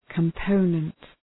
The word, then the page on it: component: {kəm’pəʋnənt}